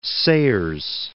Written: Sayers

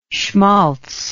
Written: shmaltz

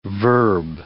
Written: verb

